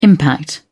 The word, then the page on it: impact: British English